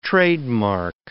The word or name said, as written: trademark